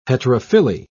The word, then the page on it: heterophylly: British English